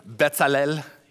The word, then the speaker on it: Bezalel: Which, by the way, did you guys notice last week how Kirk was like pronouncing him in the perfect Hebrew pronunciation? Bezalel, he was saying that.